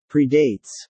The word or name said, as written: predates